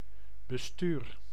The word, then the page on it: conseil: /kɔ̃.sɛj/; France